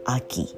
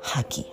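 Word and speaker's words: 秋: c'est a k Aki. C'est pas Haki, ok Parfois, vous confondez un peu H et A. Donc, c'est Aki.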